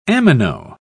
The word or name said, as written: amino